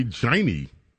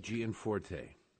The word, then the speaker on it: Gianforte: I want to say Giney. – Okay. – How do you pronounce this guy's name? – Greg Gianforte.